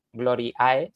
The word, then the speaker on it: Gloriae: Si escriben Verbum Gloriae, se escribe en, latín, se escribe Gloriae y se pronuncia Gloria.